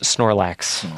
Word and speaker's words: Snorlax: Excuse me, that's pronounced Snorlax.